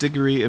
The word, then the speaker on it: Diggory: It's actually, it's pronounced Diggory in modern English. The name in Middle English and Old French would have been Décoré, something like that. But we say it in modern English, we say Diggory.